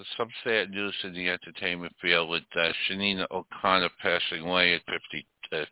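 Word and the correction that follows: Sinéad: Yeah, S-I-N-E-A-D, Sinead O'Connor.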